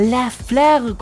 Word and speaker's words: La Fleur: La Fleur. Estás escuchando a La Fleur.